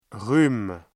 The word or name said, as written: rhume